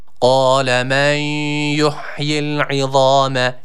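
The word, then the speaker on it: Kale (قال): Kale men yuhyil izame ve hiye ramim. – Kale kelimesi malum hatırlayacaksınız. Kale şeklinde sıkça okunuyor. Yanlış bir okuyuştur. Dikkat edelim, kaf harfi var. – Kale.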